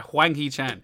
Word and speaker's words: Hwang Hee-chan: I think Neto's got like 52 points and Hee-Chan is on 49. – Yeah, I mean, I absolutely butchered his name I did there. Hwang Hee-Chan.